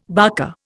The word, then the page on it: barker: Indian